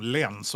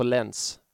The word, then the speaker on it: Lens: Jag uppskattar också att du, precis som jag, körde svenska uttalet på Lens. Man orkar inte. Man bara, det är alltså, Lens. Förlåt. – Nej, vi, nej, i den här podden säger vi Lens.